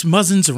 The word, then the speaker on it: Muzzins: I was reading up a little bit on it in between, and it says Boston-based Muzzins rhymes with cousins.